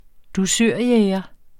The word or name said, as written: dusørjæger